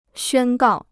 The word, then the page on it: 宣告: xuān gào